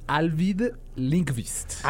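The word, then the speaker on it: Ajvide Lindqvist: John, creo que es Alvid Linkvist. – Así es, exactamente.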